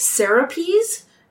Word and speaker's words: Serapis: I believe it's actually pronounced Serapis, S-E-R-A-P-I-S, to basically garner support from Greeks and Egyptians.